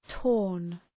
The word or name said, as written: torn